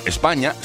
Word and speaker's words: Spagna: Sí, España se pronuncia así, España, aunque se escriba Spagna en italiano. ¿eh? Ellos dicen España directamente.